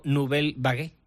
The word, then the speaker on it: Nouvelle Vague: Y ahora vamos con la película que más te llama a ti la atención de esta semana, que es un drama francés titulado Nouvelle Vague, que no sé si lo he dicho bien o no. – Sí, la Nouvelle... Ya, lo has dicho estupendamente.